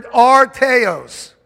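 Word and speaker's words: artios: I want you to understand now in the Greek, this word perfect comes from the Greek word arteos. It's spelled A-R-T-I-O-S. A-R-T-I-O-S.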